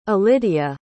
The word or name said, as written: elidia